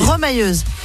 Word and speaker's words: remailleuse: Est rémailleuse. C'est ce que c'est? – Rémailleuse. – C'est ça, en rapport avec l'émail. – Remailleuse. – On dit, ça se prononce pas comme ça? Remailleuse? – Remailleuse, oui. – Ah, remailleuse, pardon.